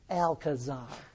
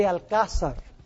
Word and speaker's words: Alcázar: His name was Luis de Alcázar. In English, those who don't know how to pronounce Spanish say Alcazar. But the proper pronunciation is Luis de Alcázar.